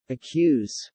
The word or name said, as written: accuse